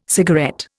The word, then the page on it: cigarette: Indian